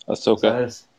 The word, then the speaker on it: Ahsoka: Uh, they finally mentioned, uh, dude, I'm going to butcher the name Asuka. Ahsoka. Ahsoka. No. God. What? What's what's the name? I can't talk today. You know, we were talking earlier, and I was saying Ahsoka. That's it.